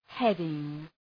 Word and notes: heading: ‘hedıŋ

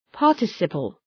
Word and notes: participle: {‘pɑ:rtı,sıpəl}

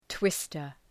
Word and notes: twister: ‘twıstər

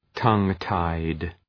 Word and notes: tongue-tied: ‘tʌŋ,taıd

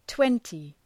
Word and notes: twenty: ‘twentı

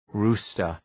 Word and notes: rooster: ‘ru:stər

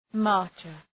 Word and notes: marcher: ‘mɑ:rtʃər

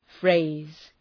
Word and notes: phrase: freız

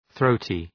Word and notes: throaty: ‘ɵrəʋtı

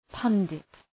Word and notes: pundit: ‘pʌndıt

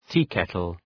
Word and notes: teakettle: ‘ti:,ketəl

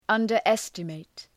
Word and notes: underestimate: ,ʌndər’estə,meıt

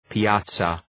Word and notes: piazza: pi:’æzə

